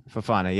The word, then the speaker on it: Fofana: Fofana, yeah.